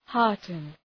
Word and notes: hearten: ‘hɑ:rtən

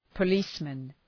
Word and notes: policeman: pə’li:smən

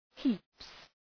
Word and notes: heaps: hi:ps

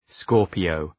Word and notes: Scorpio: ‘skɔ:rpıəʋ

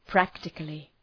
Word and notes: practically: ‘præktıklı

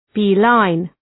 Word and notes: beeline: ‘bi:,laın

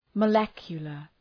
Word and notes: molecular: {məʋ’lekjələr}

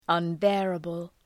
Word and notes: unbearable: ʌn’beərəbəl

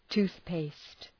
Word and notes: toothpaste: ‘tu:ɵpeıst

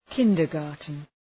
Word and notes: kindergarten: ‘kındər,gɑ:rtən

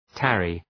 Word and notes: tarry: ‘tærı

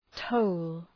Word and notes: toll: təʋl

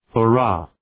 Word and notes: hurrah: hə’rɑ:

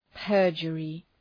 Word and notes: perjury: ‘pɜ:rdʒərı

